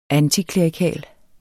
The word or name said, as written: antiklerikal